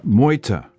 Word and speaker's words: Meute: Is it Mute? – Meute. – Meute. I was completely off.